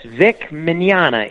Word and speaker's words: Mañana: Okay, well, the Spanish word for tomorrow is mañana. – Mañana. – And so it's Vic Mañana. It's Italian, but it's pronounced like the Spanish tomorrow.